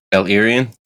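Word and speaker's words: El-Erian: I don't know if I'm saying his name right. Erian. El Erian. – El Erian.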